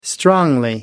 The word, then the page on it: strongly: American English